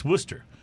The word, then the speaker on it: Worcester: It's Worcester.